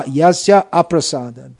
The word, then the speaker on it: yasya aprasadat: So it should be pronounced yasya, yasya aprasadat, by his non-prasad.